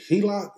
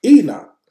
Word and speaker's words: Enoch: Helak? Am I saying his name right? – Enoch.